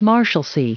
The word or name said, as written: marshalcy